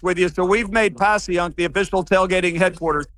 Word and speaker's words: Passyunk: Well, what I love is that you're kind of bringing that dive bar atmosphere today to the Passyunk Avenue. Is that how you say it? – Yeah, it's exactly how we say it.